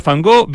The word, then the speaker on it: van Gogh: Soy Willem van Gogh, como se pronuncia en holandés. – Ah, Van Gogh. No había, nunca había escuchado ese j.